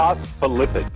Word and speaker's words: phospholipid: Phospholid? Or Phospholipid? Pid? It's got a crazy name I can't pronounce right now, but it's off Happy Birthday. – Phospholipid. – Ooh, you got it. Winner dinner. Winner, winner, chicken dinner. He's got it.